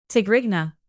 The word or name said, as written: tigrigna